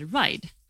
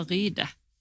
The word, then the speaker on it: Ride: Jaha, uttalar man det ride? Jag trodde man sa ryde. – Nej, ride. Ride.